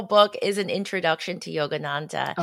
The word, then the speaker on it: Yogananda: So you mentioned Yogananda.